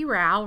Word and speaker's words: Rowe: Okay, well, I'm Becky Rowe, rhymes with wow. I always say that because it's spelled funny and people don't know how to say my name right. So Becky Rowe, rhymes with wow.